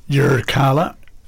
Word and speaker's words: Yirrkala: and she's a solo Indigenous act from I've got to roll your R, Yirrkala in the Northern Territory.